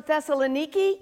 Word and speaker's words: Thessaloniki: And then we know from last week, they moved on to Thessaloniki. Yes, it's pronounced that way from what I hear.